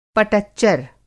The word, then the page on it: पटच्चर: Hindi